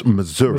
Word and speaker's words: Missouri: I believe it's pronounced Missouri.